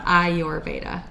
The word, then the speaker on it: Ayurveda: So I often tell people, I point at my eyeball and I say I, and then I point to them and say your Veda. Oh. I your Veda. And so that is the pronunciation.